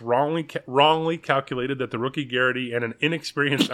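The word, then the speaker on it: Geraghty: But Ben Garrity, pretty sure that's how you pronounce it. Garaghty.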